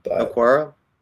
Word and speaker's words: Okwara: How do you pronounce his name again? – Okwara? – Yes.